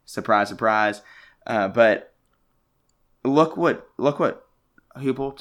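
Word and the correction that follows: Heupel: took over there. Hypel, whatever you say his name. It's pronounced Hypel. – Hypel, I believe you're right. Uh, uh yeah, Hypel. That sounds right.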